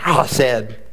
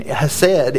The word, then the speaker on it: hesed: It's pronounced like you're, you're growling, kind of hesed, eh, eh, or you're coughing. We don't have a word for it. It gets lost in translation. Hesed is a love shown through action.